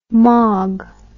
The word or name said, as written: Magh